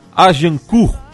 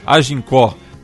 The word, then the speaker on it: Agincourt: De outubro de 1415, há exatos 600 anos atrás, ocorria a Batalha de Agincourt. A pronúncia está correta? – Agincourt. – Não, mas essa é a pronúncia francesa. A pronúncia que entra é a pronúncia dos vencedores, a pronúncia inglesa.